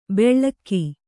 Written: ಬೆಳ್ಳಕ್ಕಿ

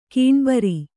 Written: ಗಣಬರು

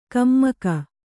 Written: ಕಮ್ಮಕ

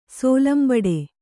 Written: ಸೋಲಂಬಡೆ

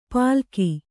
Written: ಪಲಕಿ

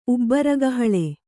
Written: ಉಬ್ಬರಗಹಳೆ

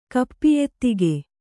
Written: ಕಪ್ಪಿಯೆತ್ತಿಗೆ

